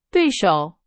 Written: 对手

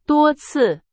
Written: 多次

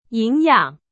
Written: 营养

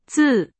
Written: 自

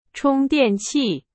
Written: 充电器

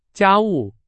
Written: 家务

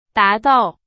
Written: 达到

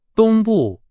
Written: 东部

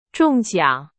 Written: 中奖

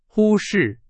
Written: 忽视

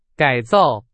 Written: 改造

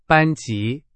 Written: 班级

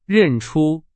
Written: 认出

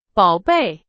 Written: 宝贝

